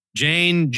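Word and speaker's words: Jeyne: Jane, it rhymes with airplane, aka Little Walder, won't grow up to be a Frey.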